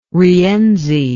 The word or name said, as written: rienzi